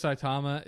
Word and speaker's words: Saitama: Saitama, right? – Mm-hmm. – Did I say that right? – Yeah, yeah. Saitama. – The kid who plays Saitama is, as I said before, like not terrible, but not great.